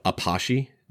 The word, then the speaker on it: Apache: No, he doesn't have a name, but he's a, you know, it's supposed to be, the act is a, it's spelled like we would spell the word Apache, but it's actually called an Apache dance.